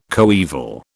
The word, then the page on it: coeval: British English